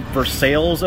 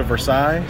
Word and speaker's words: Versailles: That's we have a, there's a place called Versailles, Ohio, as opposed to Versailles, so...